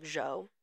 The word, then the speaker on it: Zhou: It's Zhou, but it's a lot of people call him Joe because that's kind of how it's pronounced, but it's like Zhou.